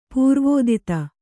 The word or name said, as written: ಪೂರ್ವೋದಿತ